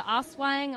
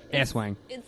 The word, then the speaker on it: Aswang: The Oswang. Oswang. – Let me see the way it's spelt. Aswang. – It's not what it is. – Why isn't it? – Because it's only one S. It's Aswang.